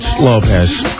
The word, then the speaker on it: Lopes: Yours is Lopes. – No, it's Lopez. But it's spelled Lopes. I don't know why it's not spelled correctly.